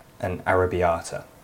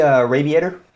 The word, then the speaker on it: arrabbiata: I used to work in a pub where we had a pizza called an arrabbiata. And this American guy came and went, "Yeah, I'll take the arrabbiator." That's my favourite mispronunciation ever.